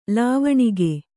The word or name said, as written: ಲಾವಣಿಗೆ